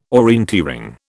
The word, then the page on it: orienteering: British English